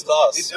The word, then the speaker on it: Clowes: C-L-O-W-E-S is how you pronounce his name. It's like, is that Klaus? Is that Klaus? – Apparently it's Klaus. – Apparently it's Klaus. That's how Dan Kordemansch pronounced it on the stage, and nobody corrected it.